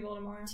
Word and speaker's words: Voldemort: Supposed to be Voldemort. – T is silent. French.